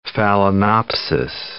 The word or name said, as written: phalaenopsis